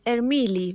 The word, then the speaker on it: Ermili: Sí, en realidad es Ermili, ahí bien está. Italiano.